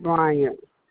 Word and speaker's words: Bryant: S-A-A-D-I-A. – S-A-A-D-I-A, correct? – Yeah, and last name, Bryant. – Could you spell that for me? – B-R-Y-A-N-T.